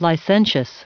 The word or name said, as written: licentious